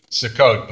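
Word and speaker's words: Sukkot: Hebrew, it's Succoth, but it's just suck it.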